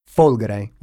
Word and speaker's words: Folgore: These characteristics of the car are expressed by the name Folgore, with the accent on the first O, which means thunderbolt, an incredible mix of power, electricity, and natural grace.